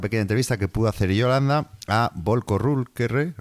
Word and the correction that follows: Ruhnke: ¿cómo se dice? – Runke, mm.